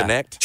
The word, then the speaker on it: Knecht: I liked the Connect kid. – He's good. – K-N-E-C-H-T, but pronounced yeah. – Connect.